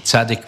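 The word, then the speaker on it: Zaddik: Bis, äh, spricht man das Zadig oder Sadik? – Zadig wäre ein richtiges Zadig.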